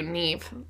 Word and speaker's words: Neve: How do you say her name? Her name always scares me. – Neve Campbell? – Thank you, Neve.